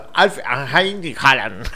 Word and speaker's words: Alf Inge Haaland: No, pero es así, Alf como Alf. – Ajá, de la sí, serie. igual. – Inge se escribe. – Ajá. – Inge Haaland. – Haaland. Ajá. – Ahora decimos todo junto. Alf Inge Haaland.